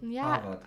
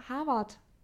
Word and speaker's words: Herwart: Oder den Sturm Herwart. – Den Herwart. – Genau. – Nicht Herwart, sondern Herwart. – Ja, Herwart. Mit W.